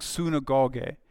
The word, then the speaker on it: synagoge: The first one, if you're familiar with the word synagogue, in the Greek it's pronounced something like synagoge.